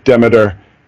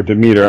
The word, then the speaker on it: Demeter: and that is the myth of Demeter and Persephone. Or Demeter. I mean, you can pronounce it either way,